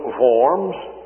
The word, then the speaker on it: Worms: He appeared before Charles V and the electors at the Diet of, well, we would pronounce it Worms, the Diet of Worms, some Diet, wouldn't it be? But really, the Germans pronounce it Worms, so that helps us out a little bit.